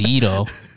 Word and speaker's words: gyros: Gyros? – Yeah. – Yeah, yeah. Yeah. – Plus Mediterranean food. – Yeah, Mediterranean. – You mean gyros? – First of all, it's pronounced Yido. And they're delicious.